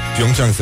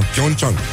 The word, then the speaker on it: Pyeongchang: bojurică, bojurică, ce face băieții, ce mai face juniorii Um, Vești extraordinare deja de la Olimpiada de la Pyeongchang Pyeongchang se pronunță, da? Pyeongchang Toată viața am spus doar Pyeongchang și uite că acum eforturile se merită, ca să zic așa.